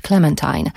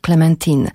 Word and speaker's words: Clementine: że powinnam to nazwisko wymówić Clementine, ale on sam, będąc tu przy Myśliwieckiej kilkanaście miesięcy temu, zażyczył sobie, żeby go wymawiać z francuska Clementine,